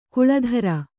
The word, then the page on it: ಕಳಾಧರ: kaḷādhara